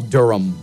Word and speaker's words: Durham: Actually, well, that's the name of the uh, the city in North Carolina, Bull Durham.